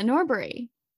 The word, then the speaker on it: Norbury: Yeah, Norbury.